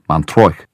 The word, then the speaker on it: Montreuil: That is Montreuil, pronounced Montreuil, one of many places in Spain, where there are also the streets of Santiago in the rain.